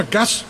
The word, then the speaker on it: Gas: Wir sagen im Norden übrigens immer Gas, also mit ganz kurzem A, weil wir hoffen, je kürzer wir das aussprechen, es nicht so teuer wird.